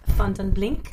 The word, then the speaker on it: Vandenblink: You know, Nikki, it's Dutch. So in Dutch, it's pronounced Vandenblink.